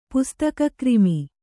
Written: ಪುಸ್ತಕಕ್ರಿಮಿ

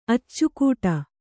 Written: ಅಚ್ಚಿಕೊಡು

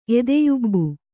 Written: ಎದೆಯುಬ್ಬು

